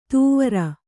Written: ತುವರ

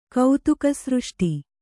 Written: ಕೌತುಕಸೃಷ್ಟಿ

